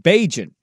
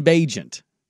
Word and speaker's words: Bagent: I told you how to say his last name last week, Bagent. T- Tyson Bagent.